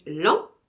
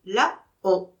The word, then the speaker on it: Laon: Vous connaissez l'an? Je prononçais l'an. L'an, vous voulez dire? Et il prononçait la-on. La-on ou l'an, c'est la même chose. Seulement, on écrit la-on et on dit l'an.